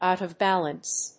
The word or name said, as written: out of balance